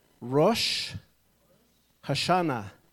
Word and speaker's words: Rosh Hashanah: We're going to speak on, everybody say with me, Rosh Hashanah. That's how you say it in Hebrew.